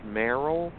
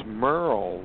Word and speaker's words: Merrell: TJ, actually, my parents spelled my name like it should be pronounced Merrel, but it's Merrel, like Merle Haggard. – All right, Merrel. – Yeah. – Okay, so, I saw M-E-R-R-E-L-L, so I apologize.